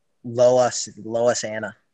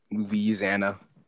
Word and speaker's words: Louisiana: Or you can go to Loisana. You can go to Loisana. – Louisiana.